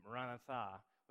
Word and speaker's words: Maranatha: And it's pronounced different ways, but I always kind of think of it as Maranatha, which translates to, and can be translated different ways, but the main thrust of it is come, O Lord, return.